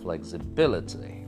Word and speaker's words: flexibility: So the B-I is getting the stress. Flexibility.